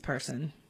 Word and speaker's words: Person: And it's spelled like Pearson, but it's Person.